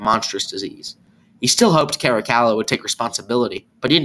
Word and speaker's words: Caracalla: I thought it would be funny to pronounce it like that, but I'm just going to stick with Caracalla now.